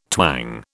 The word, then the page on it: twang: British English